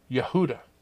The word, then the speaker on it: Yehuda: In Hebrew, Judah is pronounced Yehuda.